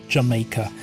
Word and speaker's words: Jamaica: Spricht sich nicht umsonst so wie die Insel Jamaica.